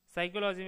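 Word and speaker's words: psychology: जैसे नाइफ में के साइलेंट है, साइकोलॉजी में पी साइलेंट है, नॉलेज में के डब्ल्यू और डी तीन ध्वनियाँ साइलेंट है।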